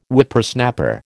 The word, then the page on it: Whippersnapper: American English